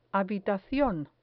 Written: habitacion

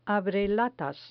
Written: abrelatas

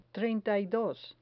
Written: treinta y dos

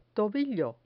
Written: tobillo

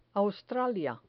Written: australia

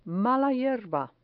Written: mala hierba

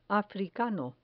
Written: africano

